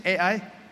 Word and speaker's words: Ai: By the way, I pronounce this as AI. Okay? So Joshua sent people to AI. It's up to you. We don't have to argue to death. You want to pronounce this as I? Okay. I? Okay. I pronounce it as AI.